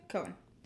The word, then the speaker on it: Cohen: Cohen, is that how you say his name? – Cohen.